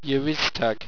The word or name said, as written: yeriztag